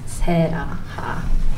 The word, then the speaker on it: Sarah Ha: My name is Sarah Ha. – My family would pronounce my name as Sarah Ha.